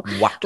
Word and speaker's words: Watto: Did I say that right? – A lot of junk. – Yes, you got it. – Beautiful. Watto.